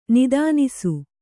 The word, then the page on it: ನಿದಾನಿಸು: nidānisu